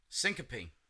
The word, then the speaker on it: syncope: So the first one is called Syncope. – Syncope. – Yes. – Hey, you said it right. Good job.